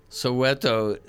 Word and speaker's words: Soweto: He did a version of Soweto is where it's at. Did I pronounce that correctly? – Yeah, yeah, yeah, yeah.